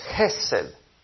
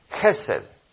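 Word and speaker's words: Hesed (חסד): Y la palabra en hebreo es Hesed. Hesed. A ver si la pueden pronunciar conmigo, hermanos. A ver, Hesed. Amén. Hesed.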